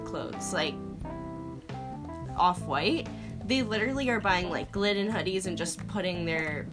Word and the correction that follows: Gildan: I think it's Gildan. – Or Gildan, yeah, they're Gildan hoodies.